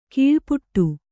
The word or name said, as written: ಕೀಳ್ಪಡು